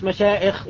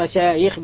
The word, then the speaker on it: مشايخ: هنا في تورية ظهر الكلام، ما تهمزوش المشايخ، يعني الهمز الهمز واللمز ويل لكل همزة اللمزة، ولكن قصده ما تنطقوهاش مشايخ، انطقوها مشايخ بالياء، عايز يصوب لهم خطأ لغة.